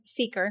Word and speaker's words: Sieker: Sieker.